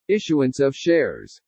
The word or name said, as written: ISSUANCE OF SHARES